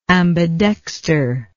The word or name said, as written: ambidextral